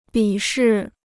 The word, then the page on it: 鄙视: bǐ shì